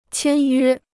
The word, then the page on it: 签约: qiān yuē